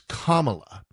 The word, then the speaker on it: Kamala: It kind of looks like Kamala or Kamala, Kamala, and it's Kamala.